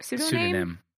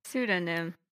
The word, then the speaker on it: pseudonym: She's mostly protected by a pseudonym. How do you say that? – Pseudonym. Like cinnamon. Pseudonym. – There we go. – Yeah, it's like synonym.